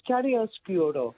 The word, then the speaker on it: chiaroscuro: just remember Chiaroscuro.